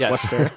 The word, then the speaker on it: Wooster: Now I know it's Worcester. – Anyway, yes, it is spelled Wooster, but whatever.